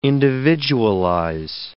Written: individualize

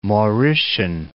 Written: Mauritian

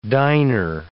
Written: diner

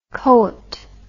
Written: coit

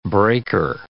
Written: breaker

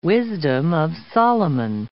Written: Wisdom of Solomon

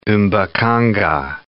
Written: mbaqanga

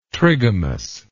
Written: trigamous

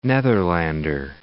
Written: Netherlander

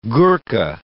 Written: Gurkha

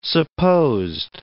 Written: supposed

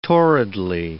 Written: torridly

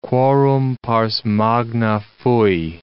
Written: quorum pars magna fui

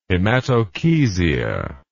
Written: hematochezia

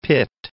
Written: Pitt

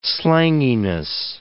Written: slanginess

